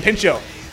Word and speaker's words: Pinchot: Pinchot.